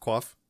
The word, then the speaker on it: quaff: Uh, uh, quaff is a verb, pronounced K W O F, quaff.